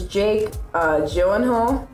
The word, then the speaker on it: Jake Gyllenhaal: Next up is Jake Gyllenhaal.